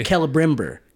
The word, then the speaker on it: Celebrimbor: And I always said it Celebrimbor or Boar, right? But it depends what part of the country you're from, I guess. but they use the hard C.